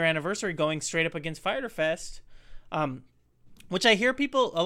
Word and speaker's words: Fyter Fest: So everyone, if you're listening to this and you don't understand what that is, even though it has a Y in there, it might be confusing. It's just Fyter Fest. It's spelled funny.